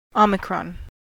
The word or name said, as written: omicron